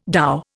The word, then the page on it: dhow: Indian